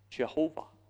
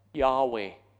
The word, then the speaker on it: Yahweh: Older translations spell out the four letters, put in the vowels, and they come up with Jehovah. Most modern scholars think it's pronounced rather Yahweh.